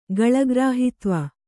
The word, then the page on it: ಗಲಗ್ರಾಹಿತ್ವ: galagrāhitva